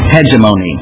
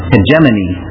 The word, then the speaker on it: hegemony: Hegemony, with a long O, is one of the pronunciations, and the other one is hegemony. You like that? It's like Hermione in the Harry Potter stories.